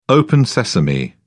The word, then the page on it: open sesame: British English